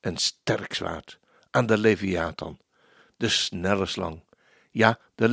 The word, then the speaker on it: Leviathan: Want al kunnen wij mensen de Leviathan niet overwinnen, Jesaja profeteert de ondergang van het beest. Op die dag zal de Heere vergelding doen. Ik lees dit vers nog eens, want de klemtoon lag verkeerd.